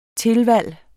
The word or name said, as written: tilvalg